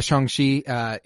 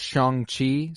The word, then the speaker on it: Shang-Chi: Yeah, Shang-Chi. He was referred to by Wong as Shang-Chi, so I'm comfortable going with the ch sound for chi. I do know, however, it is Shang.